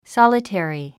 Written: solitary

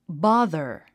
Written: bother

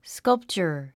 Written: sculpture

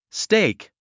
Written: stake

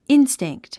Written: instinct

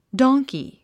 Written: donkey

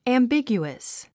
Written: ambiguous

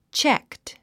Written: checked

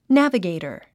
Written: navigator

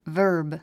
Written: verb

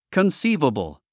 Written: conceivable

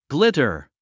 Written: glitter

